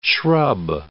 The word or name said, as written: shrub